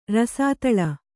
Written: ರಸದಾಲೆ

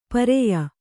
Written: ಪಾರಿಯ

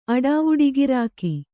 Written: ಅಡಾವುಡಿಗಿರಾಕಿ

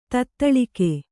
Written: ತತ್ತಳಗೈ

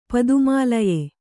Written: ಪದುಮಾಲಯೆ

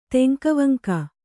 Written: ತೆಂಕವಂಕ

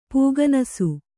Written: ಪೂಗನಸು

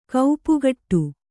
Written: ಕಾಪುಗಾಡು

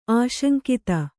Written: ಆಶಂಕಿತ

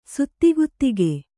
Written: ಸುತ್ತಿಗುತ್ತಿಗೆ

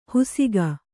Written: ಹುಸಿಕ

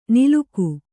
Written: ನಿಲುಕು

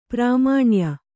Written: ಪ್ರಾಮಾಣ್ಯ

